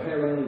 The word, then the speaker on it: Haran: So Haran is the way we kind of pronounce it.